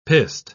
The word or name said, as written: pissed